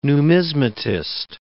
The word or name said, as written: numismatist